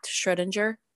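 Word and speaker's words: Schrödinger: So he bases this assumption about there being a multiverse and the choices that might branch off on an idea called Schrodinger's cat. Schrodinger? I don't know. – It would be Schrodinger because it's German. It's technically Schrodinger.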